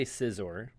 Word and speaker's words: Scizor: I'd say Scizor. – Scizor? – Yeah. – Because it's like a pair of scissors? – I guess so.